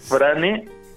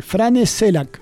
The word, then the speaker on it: Frane Selak: ¿Cómo se escribe Fran Selak? ¿Eh? – Frane. – ¿Frane? ¿Y Selak con Z? – Selak con S-E-L-A-K. – Muy bien, ahí. Frane Selak, ¿sí?